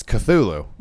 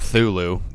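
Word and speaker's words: Cthulhu: but it's Cthulu, mm-hmm. which I'm pretty sure that's how you pronounce it. – I think so. – It's not Thulu, so like the C isn't silent.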